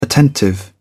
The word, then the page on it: attentive: British English